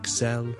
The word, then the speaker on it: excel: 个重音呢其实呢它是应该放在 the second syllable 的， so excel excel excel。 OK，同这个字有关的一个字呢，我们 excellent E-X-C-E-L-L-E-N-T excellent， to become excellent。